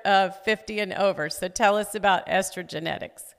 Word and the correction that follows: Estrogenics: So estrogenics is all the things in our society. – Okay. I'm sorry, I didn't say that correctly.